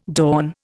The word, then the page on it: dawn: Indian